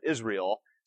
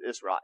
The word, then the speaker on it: Israel: Which also helps you with your spelling, though, because you'll never misspell Israel and make it I-S-R-E-A-L. Because even though people pronounce it that way all the time, Israel, it's not what it is. It's Israel.